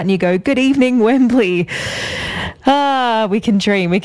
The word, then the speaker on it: Wembley: You have to say it like Wembley. You can't say Wembley Stadium. It's Wembley.